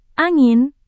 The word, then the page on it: angin: indonesian